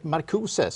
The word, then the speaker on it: Marcuse: Och då står det också i den här artikeln av Oskinis om den tysk-amerikanske filosofen Herbert Marcuse, ska vi väl uttala det då lämpligtvis.